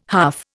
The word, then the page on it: huff: Indian